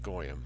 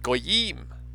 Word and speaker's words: goyim: goyim. Goyim, as it's actually pronounced. But I, frankly, maybe childishly, prefer to say goyim. It sounds better to my ear.